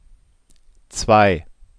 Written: zwei

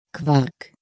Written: Quark

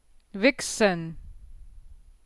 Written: wichsen

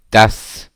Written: dass

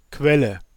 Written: Quelle

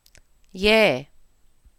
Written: jaeh

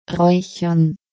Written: raechern

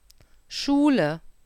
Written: Schule

